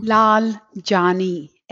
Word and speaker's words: Laljani: Laljani, L-A-L-J-A-N-I.